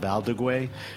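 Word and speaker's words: Valdiguié: And he does like how do you pronounce it? – Valdeguay.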